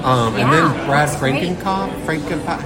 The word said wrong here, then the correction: Feinknopf: how do you say it? – Frankenkopf. – Oh, Feinknopf.